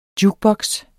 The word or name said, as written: jukeboks